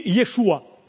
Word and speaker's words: Yeshua: Et le nom de Jésus en hébreu, ça veut dire, on le prononce ainsi, pardon, on dit Yeshua.